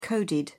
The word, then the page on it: coded: British English